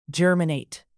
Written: germinate

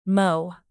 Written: mow

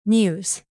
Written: muse

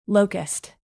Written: locust

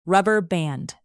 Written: rubber band

